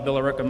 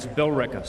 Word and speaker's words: Billerica: Billerica, Massachusetts. Actually, I said that wrong. It's Billerica, sorry. I put too many E's and R's in there. We don't do that up there.